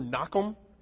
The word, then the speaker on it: Nahum: Nahum. It's actually pronounced in Hebrew, Nakum.